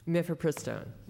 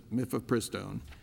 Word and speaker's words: Mifepristone: Item B, it says Ohio passed the act to ensure that, how do you pronounce this? – Mifepristone. – Mifepristone.